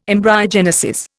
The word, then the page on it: embryogenesis: Indian